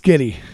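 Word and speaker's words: Schiti: How would you say that? – It's gotta be Shitty. – Shitty. – I guarantee you, it's Skitty.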